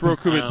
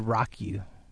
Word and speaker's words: Roku: I always said Rock-you. – Rock-you. – But it's definitely not that.